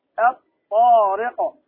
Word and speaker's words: الطارق: أمثلة الحرف الطاء، مثاله الطارق، ما تقول الطارق، ما تنطق اللام الطاء، الطارق.